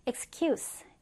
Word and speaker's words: excuse: 일단 명사로 할 때는 S 발음이에요. Excuse. Excuse. 그리고 동사로 용서하다는 약간 Z 발음으로 끝납니다.